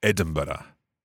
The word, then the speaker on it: Edinburgh: Sí, pues yo soy escocés, claro, y yo digo Edinburgh. – Edinburgh. – Edinburgh. – Edinburgh. – Vamos a decir cuatro sílabas, ¿ok? Edinburgh.